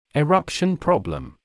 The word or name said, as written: eruption problem